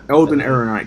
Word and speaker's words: Ehrenreich: Like, there's been rumors about, you know, the lead actor, Alden Reich, I'm not going to pronounce his name right, I'm going to call him Elden. Elden, the guy that, I think he was in another movie. – Alden Ehrenreich, because I'm going to call him Alden Ehrenreich.